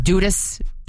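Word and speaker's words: Dudus: Now, it's D-U-D-U-S, pronounced Dudas.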